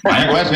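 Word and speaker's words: Mayagüez: I can't pronounce that word. Maya Guez. – Maya Guez, yeah, that's pretty good. Oh, all right, there we go.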